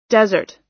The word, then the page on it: desert: American English